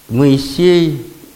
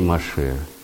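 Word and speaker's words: Моше (משה): Но мы говорим Моисей, а евреи Моше.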